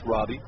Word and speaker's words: Roby: It looks like Roby, it's R-O-B-Y, but pronounced Robbie.